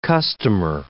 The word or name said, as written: customer